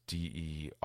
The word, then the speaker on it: Derosiers: And if you don't know how that's spelled, it's Derosiers. It's a French last name.